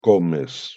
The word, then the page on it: comes: Spanish